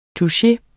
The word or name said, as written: touche